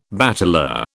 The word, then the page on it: bateleur: British English